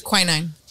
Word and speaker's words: quinine: It was created back when British soldiers stationed in the colonial Indian Wars prescribed tonic water with, I can't say this right, quinine? How is that? – Quinine. – Quinine? Is that how you say it? – Yes.